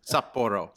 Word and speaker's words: Sapporo: Yeah, that's how you pronounce everyone out there, a little Japanese lesson. Sapporo. There you go.